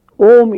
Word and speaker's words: Om: They start saying, ah, oo, but it's not so. It's just Om, A and U blend into O. And ends with M sound.